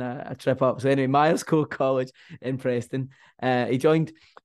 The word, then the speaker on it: Myerscough: And he studied at Myerscough, if I'm pronouncing that correctly. – Myerscough College.